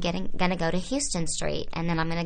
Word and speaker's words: Houston: and go to Canal and it was like a year later that someone kept saying Houston, Houston. I was like, no, it's pronounced Houston. That's where my parents live.